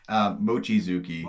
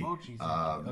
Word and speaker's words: Mochizuki: Mochizuki. – Mochizuki.